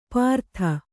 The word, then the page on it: ಪಾರ್ಥ: pārtha; kannada